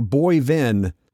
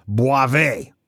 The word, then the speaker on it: Boivin: It looks like Boivin, but it's French and pronounced Boivé.